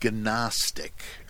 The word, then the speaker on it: Gnostic: And again, there must be more, but of course, you're not supposed to say Gnostic, right? It's a silent G, though I haven't heard too many people say that occasionally.